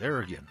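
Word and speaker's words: Airegin: I thought it was Aragon, because it's like a soft G in Nigeria. So anyway, I'm hearing it's Aragon. Okay, well, we shall see. You know, it's a made-up word, so it's Nigeria backwards, you know.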